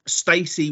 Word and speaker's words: STAYC: Bill and Ted, good pun, featuring JC Stewart, Stacy, which is spelled S T A Y C,